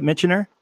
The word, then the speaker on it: Michener: Did I pronounce that right? Michener? – Yeah.